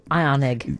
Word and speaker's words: Ioniq: It's the Ioniq or something. Ioniq. Anyone can tell us how to pronounce that? – Ioniq.